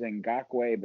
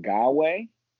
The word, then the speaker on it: Ngakoue: I thought it was Ngakoue, but now I'm hearing Ngawe. – Yes, I believe that is the proper pronunciation.